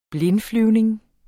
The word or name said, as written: blindflyvning